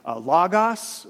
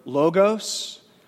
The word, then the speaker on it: logos: That word in Greek is variously pronounced. It's L-O-G-O-S. Variously pronounced depending on which Greek scholar you're talking to. Either as logos, which is how I learned it, or logos. Some people call it logos, which is actually not the agreed upon or the standard pronunciation.